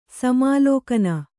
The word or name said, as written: ಸಮಾಲೋಕನ